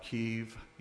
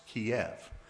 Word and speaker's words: Kyiv: And yes, if you're like me, Kyiv is the same city that I was taught to pronounce, Kiev. It is the same, and I believe now the spelling is K-Y-I-V.